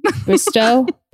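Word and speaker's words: Bristowe: I love how you call her Bristow. I'm dying right now. – What is her? How do you say her last name? Bristow? Yeah. Whatever.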